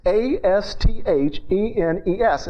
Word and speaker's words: asthenes: It is the Greek word asthenes, A S T H E N E S. It's pronounced asthenes.